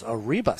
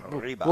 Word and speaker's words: arriba: Arriba. Arriba. Can you roll your R's? Can you do that? Arriba. Can you do that? Arriba.